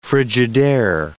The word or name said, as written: Frigidaire